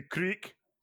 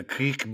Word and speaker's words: kriek: You could have the Creek. I have a Creek. – You could get a Creek beer. – Yeah. – You know what that is? – No. – It's spelled K-R-I-E-K.